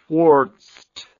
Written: quartzed

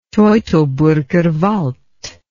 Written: teutoburger wald

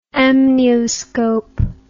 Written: amnioscope

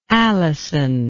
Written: alyson

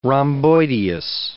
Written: rhomboideus

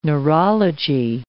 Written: neurology

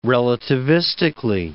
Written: relativistically

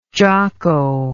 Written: jocko

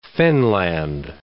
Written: fenland

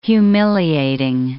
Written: humiliating

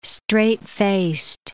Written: straight-faced

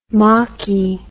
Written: mocky

